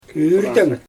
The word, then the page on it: yyrteŋ: Xyzyl